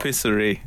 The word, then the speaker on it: tapisserie: Is that how you say it in French? – What? – Tapisserie. – I believe so.